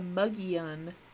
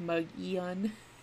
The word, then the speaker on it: Mogollon: So they have something called the Mugian. The Mugian, which is a weird name. It's funny because it's spelled Mogollon, but it's obviously a native word, so it's pronounced Mugian.